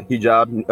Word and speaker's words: hijab: is that how you pronounce it? Uh, yeah, hijab, uh, burqa.